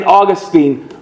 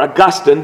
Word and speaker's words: Augustine: St. Augustine, or Augustine, depending on how you want to pronounce that, speaks of these two this way.